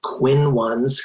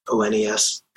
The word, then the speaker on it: Quinones: And on Instagram, Quinones, Quinones. I have that because I had a substitute teacher who pronounced my name that way, and I just latched onto it.